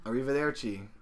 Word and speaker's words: arrivederci: No, it's arrivederci, not verci.